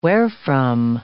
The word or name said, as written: wherefrom